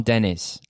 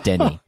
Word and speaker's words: Denis: You do not call him Dennis. I would make that mistake because I said Oppenheimer for an hour. You know me. So it's Denis. It's Denis.